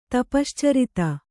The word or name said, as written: ತಪಶ್ಚರಿತ